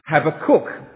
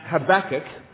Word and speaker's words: Habakkuk: The rector suggested I should pronounce it Habakkuk, as in, have a cooked breakfast. In America, I think it's pronounced Habakkuk, but I was brought up with Habakkuk, so I'll leave it up to you to decide.